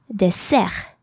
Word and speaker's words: dessert: And the stress, so the accent, the emphasis in French is put on the last syllable of the word. Therefore, this word is pronounced dessert. Dessert. Dessert.